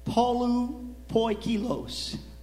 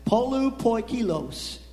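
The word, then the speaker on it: Polupoikilos (πολυποίκιλος): Okay, that's the word in pronunciation. Now, I'm sure that's not, if you're just talking Greek to one another, in this ancient Greek, you would say it differently than that, but that's the pronunciation. Polupoikilos. Okay?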